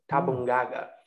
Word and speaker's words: Tabungaga: You know, so it is written in a different way, but I want to go for the original pronunciation, and that's specified in the article, that the original pronunciation in the Wanamara language is Tabungaga.